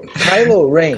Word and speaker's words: Kylo Ren: Aliás, vamos falar do Caio. Caio. – Do Caio. – Caio. Meu Deus, cara. – Kylo. Kylo Ren.